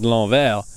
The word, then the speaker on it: l'envers: Like in the word l'envers, it's really le envers, so it's L apostrophe envers, and there's an S at the end that you don't pronounce.